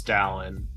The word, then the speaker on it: Dallin: So you got the last name perfectly. The first name is Dallin.